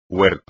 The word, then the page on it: huerto: espagnol